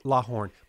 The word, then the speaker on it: Lawhon: Lawhorn, but it's spelled L A W H O N.